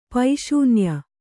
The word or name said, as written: ಪೈಶೂನ್ಯ